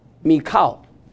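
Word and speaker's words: Michal: Anyone know how to pronounce it? Including all your PhDs? Pardon? – Michael. – Okay.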